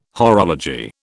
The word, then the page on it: horology: British English